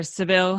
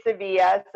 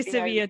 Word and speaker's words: Sevilla: And how, and at what stage did you start narrowing in on Sevilla or Seville? I don't know how you want to pronounce it. – I like to say Sevilla. – I say Sevilla too, but some people look at me like, what are you talking about?